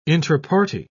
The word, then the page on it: intraparty: British English